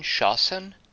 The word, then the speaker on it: Shasn: What's the word you're saying? Shasan? – Yeah, it's spelled Shasn.